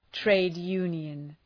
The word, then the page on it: trade union: ,treıd’ju:njən